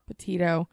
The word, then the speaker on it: Petito: Petito.